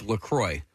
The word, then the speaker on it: LaCroix: Okay, so everybody I know calls that beverage LaCroix. – Mm-hmm. Oh, okay. Am I pronouncing it incorrectly? – No, you're pronouncing it correctly.